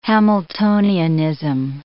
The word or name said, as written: Hamiltonianism